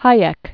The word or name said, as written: Hayek